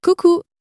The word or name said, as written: coucou